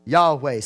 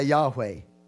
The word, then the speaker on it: Yahweh: Y'all have heard us say, make reference to that, or sometimes pronounce Yahweh. Okay? This name here is masculine. Yahweh is masculine. This is God in his masculine nature or characteristic. Yahweh, say Yahweh.